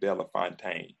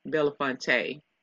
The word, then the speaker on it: Belafonte: Is his name pronounced Harry Belafonte or is it Belafontaine? – Belafonte.